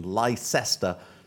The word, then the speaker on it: Leicester: That is the correct pronunciation. Leicester City.